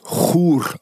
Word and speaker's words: Chur: Die Schweizer sprechen es tatsächlich Chur aus.